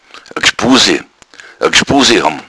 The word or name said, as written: gschbuse